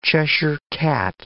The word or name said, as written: Cheshire cat